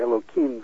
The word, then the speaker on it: Elohim: Of course, I say Elohim. The last syllable is him, but I always say Kim because I don't want to pronounce God's name.